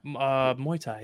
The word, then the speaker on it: Muay Thai: Muay Thai? – Yeah, Muay Thai, yeah.